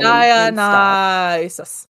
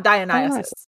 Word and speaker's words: Dionysus: Dionysus. Dionysus. Sounds right. Dionysus. Not Dionysus. – Dionysus. – Dionysus. – Too much infamous on the I.